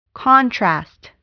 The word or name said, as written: contrast